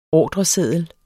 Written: ordreseddel

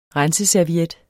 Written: renseserviet

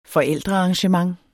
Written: forældrearrangement

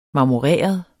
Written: marmoreret

